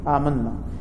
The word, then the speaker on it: amanna: Amanna is a past tense. And when 2-3 times we have corrected this word, the pronunciation amanna, we have to pronounce alif at the end also, and this is called basic mad or al maddul asli.